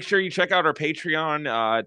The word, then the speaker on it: Patreon: Patreon, I believe, is how it's pronounced. – I think so. – What were you pronouncing it as? – Patron. – No, that's technically could also be that, but I think it's like Patreon's the name of the website.